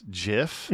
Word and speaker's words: GIF: Because the creator does say that it's pronounced GIF, but that's really stupid.